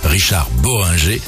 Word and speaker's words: Richard Bohringer: Richard Bohringer, je vous en ai parlé tout à l'heure. Richard Bohringer, je le prononce.